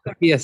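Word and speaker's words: Pākehā: and the Pakeha, is that how you pronounce that word? – Pakeha section.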